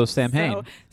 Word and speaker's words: Samhain: Samhain is spelled S-A-M-H-A-I-N. – So Samhain.